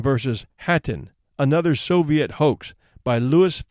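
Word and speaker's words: Khatyn: Hatyn, in which the KH is pronounced like the English H, is a small village some 30 miles to the northeast of Minsk, the capital of Belarusia.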